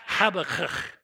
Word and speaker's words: Habakkuk: Everybody on three, pronounce the name Habakkuk. Ready? One, two, three. Habakkuk. Now say it again this time, and just this side of spitting on your neighbor, because it really gets there.